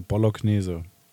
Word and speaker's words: Bolognese: Das heißt aber, also äh, der, äh, die korrekte Aussprache ist äh, Bolognese.